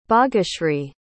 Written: baageshree

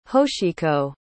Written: hoshiko

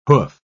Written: hoof

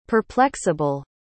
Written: perplexable

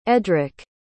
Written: eddrick

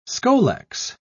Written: scolex

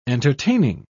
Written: entertaining

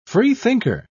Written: freethinker

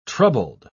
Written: troubled

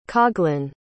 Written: coughlan

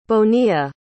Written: Bonilla

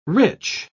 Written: rich

